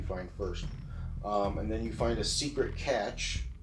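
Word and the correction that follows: cache: Did I say that right? Catch? – Cash.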